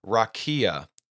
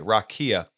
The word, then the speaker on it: raqia: And it is the Hebrew word rakia. If you're going to spell it in English, R-A-Q-I-A, rakia.